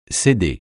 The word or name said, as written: céder